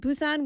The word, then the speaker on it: Busan: In Scotland, we don't say Busan. Busan, short A. In Busan, we say Busan.